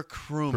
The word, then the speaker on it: Krumme: K-R-U-M-M-E. Crummy. – Connor Crummy.